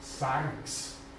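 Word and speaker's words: Sanks (σάρξ): Nächstes, äh, Sanks. Sigma, Alpha, Rho, Xi. Betonung auf dem Alpha, das ist die Fehler jetzt in der Angabe, die werden wir mir sagst.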